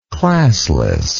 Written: classlessness

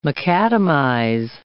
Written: macadamize

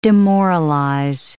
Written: demoralize

